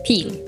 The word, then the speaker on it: Peel: Is it Peel or is it different? – It's a long E, it's Peel. – Peel, okay.